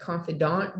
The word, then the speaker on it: confidant: because you always have that confident, how do you say it, confidant there,